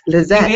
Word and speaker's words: Lizette: Is it Lizette? – Lizette. – It is Lizette. Okay, I am right. – It's like Josette, but Lizette.